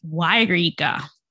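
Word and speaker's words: Yreka: No, they say it's pronounced Y-Rika.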